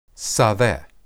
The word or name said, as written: sadeh